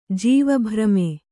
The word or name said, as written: ಜೀವಭ್ರಮೆ